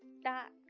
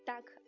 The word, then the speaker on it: dock: 这里还要注意一下这个码头这个单词哈 dock. 它呢是一个长啊音，千万不要读成鸭子 duck.